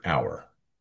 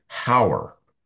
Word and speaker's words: hour: He starts off with the word hour and asks the audience why we don't pronounce the H in the word. Why is the H silent? Why don't we pronounce the word as hower?